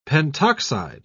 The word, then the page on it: pentoxide: British English